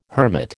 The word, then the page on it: hermit: American English